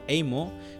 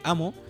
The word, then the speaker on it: Amo: Eh, eh, de hecho, en su último disco que se llama Amo, que se llama se escribe Amo. – Eh, era No era emo, es amo, amo, del 2019.